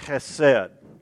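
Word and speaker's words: chesed (חֶסֶד): It's the Hebrew word chesed. You got to say it like you're clearing your throat.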